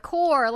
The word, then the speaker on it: COER: But it's spelt C-O-E-R. They did reveal their fandom name. It was after 100 days since debut. So, that was really, really... I feel like that was a good time for them to announce a fandom name. So, like I said, it's Core and pronounced as, like, C-O-R-E.